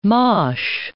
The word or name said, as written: mache